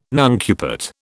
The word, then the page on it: nuncupate: British English